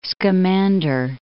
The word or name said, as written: Scamander